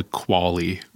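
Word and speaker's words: QALY: And it struck me, I guess, because in that interview, he talked about this idea of a quality-adjusted life year, what's called a quali, or at least that's the pronunciation that I've chosen to give it.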